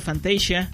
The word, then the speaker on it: Fantasia: Bueno, en español sí se pronuncia Fantasía, en inglés se pronuncia Fantasia.